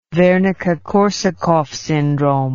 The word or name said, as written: wernicke korsakoff syndrome